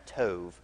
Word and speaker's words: tov: Now, in the kind of English writing of it, it has a B in it, but there's not really a B, or because there's not a V in Hebrew, so it's got a B there, but we pronounce it tov. So you're going to see it written in the future as tov.